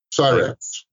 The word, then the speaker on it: Cyrix: Cyrix.